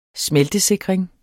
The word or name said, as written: smeltesikring